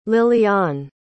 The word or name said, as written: liliane